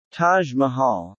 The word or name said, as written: Taj Mahal